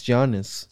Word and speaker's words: Giannis: It's Giannis. – Yeah, there we go. Oh, Giannis. I don't even know his last name. I just say go. I could just go with Giannis.